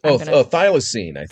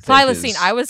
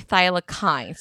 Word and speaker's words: thylacine: It's the, it's spelled Thylacine. And I feel like, thylacine. – Thylacine. I was close. I was going to say thylakine. So I was pretty close.